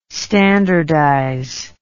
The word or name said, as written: standardize